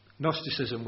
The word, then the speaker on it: Gnosticism: I always want to say Gnosticism because it begins with a silent G. The Gnostics. Gnosticism was very influential in the New Testament times and maybe for 200 years after that.